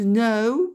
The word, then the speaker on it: know: To know, bien sûr, le K ne se prononce pas, I knew, known.